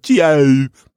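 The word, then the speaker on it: ciao: But one interesting thing about it is that somebody does say chow. It's spelled funny. It's like chow.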